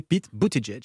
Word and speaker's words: Buttigieg: On ne dit pas 2 fois Edge ? – Non, parce que moi je regarde les late shows américains et j'ai vu les humoristes prononcer Pete Buttigieg.